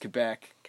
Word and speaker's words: Quebec: Quebec. It's pronounced Quebec.